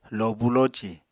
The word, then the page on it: lobu loci: Apatani